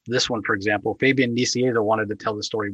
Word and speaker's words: Fabian Nicieza: Fabian Nicieza.